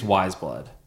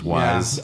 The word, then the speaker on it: Weyes Blood: The one with I don't know how to pronounce the name she goes by, but it's spelled Weyes Blood. – Oh, I think it's I think it's Wise Blood. – Just Wise.